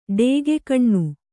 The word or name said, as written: ಡೇಗೆಕಣ್ಣು